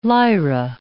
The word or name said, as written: Lyra